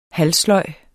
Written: halvsløj

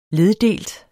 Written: leddelt

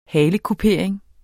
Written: halekupering

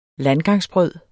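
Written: landgangsbrød

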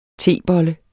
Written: tebolle

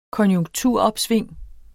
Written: konjunkturopsving

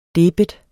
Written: debet

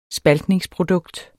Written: spaltningsprodukt

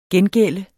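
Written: GENGÆLDTE

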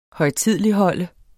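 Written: højtideligholde

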